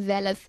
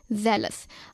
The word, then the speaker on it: zealous: और हमारा इंग्लिश का वर्ड है ज़ेलस, जिसकी स्पेलिंग है ZEALOUS, ज़ेलस। अब सुनने में ये थोड़ा जेलस जैसा लगता है, लेकिन दोस्तों इसकी मीनिंग जो है ना वो बिल्कुल अलग है।